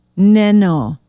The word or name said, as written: Nenno